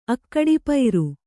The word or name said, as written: ಅಕ್ಕಡಿಪೈರು